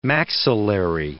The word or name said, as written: maxillary